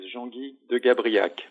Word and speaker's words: Jean-Guy de Gabriac: My name is Jean-Guy de Gabriac, French.